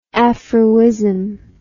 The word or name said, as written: afroism